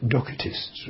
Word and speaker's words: Docetists: Purists in the language would say Docetists, really, because it's a short E. But Docetists, D-O-C-E-T-I-S-T-S.